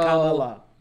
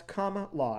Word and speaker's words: Kamala: Comma, space, law. – Where my news source that I get all my news from was like, pronounce comma, law. And I'm like, I've been saying it wrong.